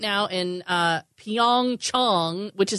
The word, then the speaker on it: Pyeongchang: Everybody's saying Pyeongchang. It's Pyeongchang. – According to my South Korean friends. We're all saying it wrong.